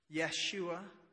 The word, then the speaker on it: Yeshua: This gets lost a little bit in our English translations, but Jesus is the same Hebrew name for Joshua that we have in the Old Testament. Yeshua, the Hebrew pronunciation, which means the Lord saves.